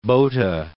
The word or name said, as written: Bothe